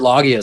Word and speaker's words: Loggia: I was thinking of, I don't know how to pronounce his last name, Robert Loggia, Loggia.